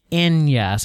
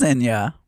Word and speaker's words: Enya: All right, I'm being told that it's Enya, so I guess you were saying it correctly. – Well, it is Enya, but I don't listen to it.